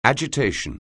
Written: agitation

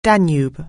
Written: Danube

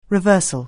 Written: reversal

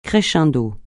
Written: crescendo

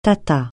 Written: tata

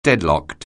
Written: deadlocked

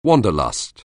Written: wanderlust

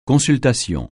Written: consultation

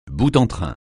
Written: boute-en-train

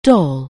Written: doll

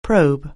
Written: probe

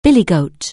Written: billy goat

